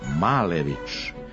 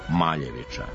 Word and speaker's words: Malević: Zapravo, on je sada Malević, iako sam ga i ja, kao i vi, tokom školovanja upoznala kao Maljevića.